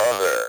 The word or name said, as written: other